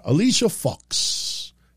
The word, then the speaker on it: Alicia Fox: Alicia Fox.